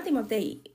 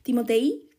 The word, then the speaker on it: Timotei: No, se llama Timotei. Eh, cuesta dos euros, creo. Eh, lo compré en el Gadis, yo creo que están todos lados. Timotei, tiene acento en la I.